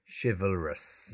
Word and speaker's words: chivalrous: Yeah, chivalrous. So we have to be careful with the pronunciation here. Chivalrous. Chivalrous. Okay, and it's spelled C-H-I-V-A-L-R-O-U-S. So chival, yeah, so they're the first two syllables.